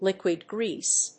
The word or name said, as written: liquid grease